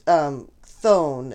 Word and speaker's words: Chthon: And it's called Thone. It's spelled C-H-T-H-O-N.